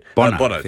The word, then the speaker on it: Bono: Bono, thank you.